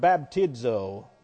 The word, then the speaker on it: baptizo: comes from that Greek word baptizo, B-A-P-T-I-Z-O, baptizo, or baptizo, B-A-P-T-I-Z-O, long O, baptizo.